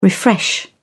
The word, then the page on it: refresh: British English